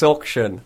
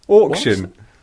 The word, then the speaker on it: auction: Why do you say it like it's got an O at the start? – Auction. – Auction. – That's how it's pronounced. – Like octopus. Auction. Auction.